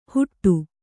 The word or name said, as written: ಹುಟ್ಟು